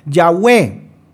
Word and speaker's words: Yahweh: Los judíos pronuncian el nombre de Dios en el Antiguo Testamento como Yahweh.